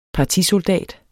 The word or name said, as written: partisoldat